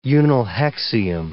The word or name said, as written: unnilhexium